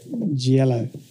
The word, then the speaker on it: Gioiello: My last name is said Gioiello. So it's like G, then yellow. – Gioiello.